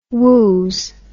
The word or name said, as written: wooz